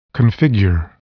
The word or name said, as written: configure